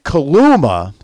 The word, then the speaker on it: Kaluma: And Kaluma, do I got his name right? – You got it.